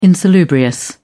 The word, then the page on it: insalubrious: British English